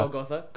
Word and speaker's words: Golgotha: Golgotha.